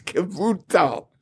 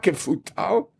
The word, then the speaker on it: Kavutal: So Rav says, Kaputal, bays, Kavutal. So he's machvel with his hands, he makes this bays. Pronounce that Kavutal, not Kefutal.